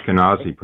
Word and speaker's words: Ashkenazi: I recently just found out that, and correct me because I'm going to pronounce this wrong, but I have Ashkenazic Jew in my genes. Is it, oh my goodness. – Ashkenazi, probably. Ashkenazi.